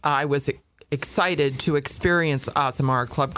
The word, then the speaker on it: Azamara: And we'd like to welcome to the program from Azamara Club Cruises. Now, did I say it right? – You did. Azamara Club Cruises.